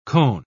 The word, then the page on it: koan: British English